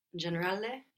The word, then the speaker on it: generale: Generale, General.